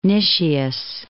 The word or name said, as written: Nicias